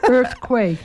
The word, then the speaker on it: earthquake: Het is een earthquake, denk ik. Het is een earthquake. – Oh ja, earthquake, sorry. Mijn uitspraak was niet helemaal politiek correct.